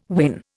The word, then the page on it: win: Indian